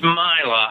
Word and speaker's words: Mila: It's Mila. I've actually heard it pronounced both. I had to spend some time trying to figure this out myself, but it seems like she said Mila.